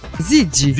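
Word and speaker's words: Ziggy: Abbiamo appena ascoltato Gigi Marley. – No, attenzione, no, Gigi. – Zigi. – Zigi, Zigi. – Perché io ho la pronuncia della Z che è un po' particolare.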